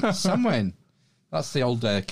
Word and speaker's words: Samhain: Well, it's spelt Samhain, but it's pronounced Samhain. – Samhain. – Yeah, flip that M upside down. – Samhain. – Turn that frown. – turn that into a wane.